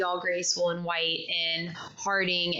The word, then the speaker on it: Harding: I mean, I know that Hardigan, wait, why can I not get her name right? I'm combining names. You're trying to combine them. – I'm combining names. Harding, she like wore different outfits and stuff whenever you practice.